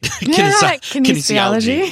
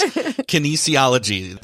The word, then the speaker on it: kinesiology: Kinesiology. – Yes. Kinesiology.